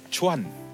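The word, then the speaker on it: cuan (船): Yang pertama-tama ini bacanya cuan. Cuan, bukan cuan, bukan cuan untung, cuan ya.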